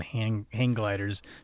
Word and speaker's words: hang gliders: How do you say hand gliders? – Hang gliders? – Hang gliders. Hang gliders. Okay.